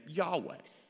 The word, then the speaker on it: Yahweh: And so we believe that the best way to pronounce that name is the name Yahweh.